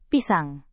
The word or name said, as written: pisang